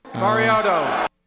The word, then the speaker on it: Mariota: Mariota.